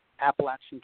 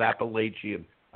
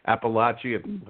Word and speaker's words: Appalachian: We have 23 member districts, over 150 schools, over 50,000 students, and over 5,000 teachers and administrators that we serve in Appalachian, Kentucky. – Wow. Wow. And by the way, note the pronunciation, everybody, not Appalachian. I used to pronounce it like that. I have learned from KVEC, Appalachian, okay?